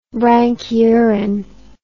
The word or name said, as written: branchiuran